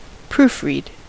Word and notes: proofread: American English; [ˈpɹuːf.ɹiːd]

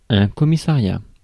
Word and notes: commissariat: France; [kɔ.mi.sa.ʁja]

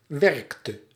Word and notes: werkte: /ˈʋɛrk.tə/

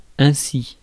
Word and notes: ainsi: [ɛ̃.si]; France

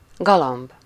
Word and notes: pigeon: [pi.ʒɔ̃]

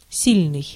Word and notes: powerful: American English; [ˈpa.ʊə.fʊl]

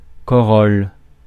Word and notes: corolle: France; [kɔ.ʁɔl]

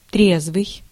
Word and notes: sober: American English

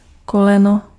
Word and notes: koleno: /ˈkɔ.lɛ.nɔ/

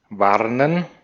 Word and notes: warnen: /ˈvaʁnən/; /ˈvaːnən/; /ˈvaːnn̩/

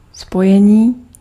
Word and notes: link: American English; /lɪŋk/

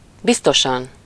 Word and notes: definitiv: /ˌdefiniˈtiːf/

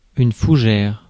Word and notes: fougère: France; [fu.ʒɛʁ]

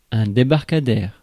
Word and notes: débarcadère: France; [de.baʁ.ka.dɛʁ]

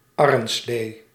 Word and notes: arrenslee: /ˈɑrə(n)ˌsleː/

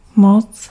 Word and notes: force: American English; GenAm; IPA: /ˈfɔɹs/; IPA: foəɹs/

